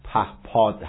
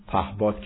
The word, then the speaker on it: پهپاد: پهباد پیشرفت این کشور ارزیابی کرد. البته تلفظ صحیح پهپاد هست و نه پهباد که به عادت بعضا اشتباه میگوییم.